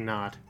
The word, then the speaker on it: knot: No, it's like you tie a knot. – Oh. See, I can't read the K. I didn't even see it there. – It's a silent K.